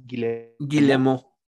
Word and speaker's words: Guillemot: Eh, otra noticia que tenemos, el CEO de Ubisoft, que es, eh, ayúdame con la pronunciación, Ibs Guillemot.